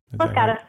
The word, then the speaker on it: Los Gatos: Am I saying that right? Los Gatos? – Yeah, Los Gatos.